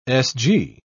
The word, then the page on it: sg: British English